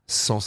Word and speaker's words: Cent: Sans, ça s'écrit C-E-N-T. Et puis le morceau Sans nuit, sans toi. Sans avec, évidemment, le nombre 100.